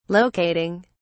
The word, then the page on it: locating: British English